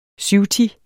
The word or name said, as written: syvti